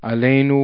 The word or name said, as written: alenu